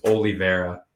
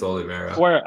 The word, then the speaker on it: Oliveira: Well, if you pronounce his name right, then maybe I could give you my prediction. – But how did I say it wrong? – It's Oliveira. – Oliveira. – Yeah, it's Oliveira.